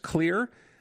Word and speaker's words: Xlear: Nathan Jones, it is called, it's spelled X-L-E-A-R, but pronounced clear.